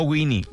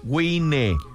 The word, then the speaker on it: Wine: Visite en Facebook Baja Wine, se escribe Baja Wine, no Winnie, Wine.